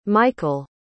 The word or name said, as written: mikael